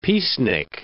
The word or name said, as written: peacenik